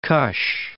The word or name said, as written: Kush